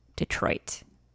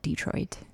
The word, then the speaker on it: Detroit: And anyone who is from that area calls it Detroit. Emphasis on the Troit. – Yeah. – And I never realized I was saying it wrong until someone had to correct me. But I'm hard to train because I'm from Massachusetts, so I still call it Detroit. D, the emphasis on the D.